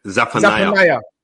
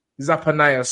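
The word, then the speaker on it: Zephaniah: I think, how you say it? Zappadia? – Zappanaya. – Zappanaya, sorry.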